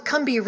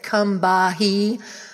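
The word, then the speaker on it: Combahee: So my mission is to tell you about that mission up what's called the Cumbie River. I'm pronouncing it as they would in South Carolina, by the way. If you saw it on a piece of paper, you might think it said Cumbahee.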